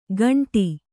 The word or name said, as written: ಖಾಂಡಿ